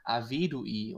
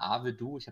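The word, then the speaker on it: Arvedui: ja Avedui und ich habe immer Avedu, ich habe das so versucht, lateinisch, französisch, wegen irgendwelchen Befindlichkeiten auszusprechen. Aber er wird, glaube ich, tatsächlich Avedui ausgesprochen.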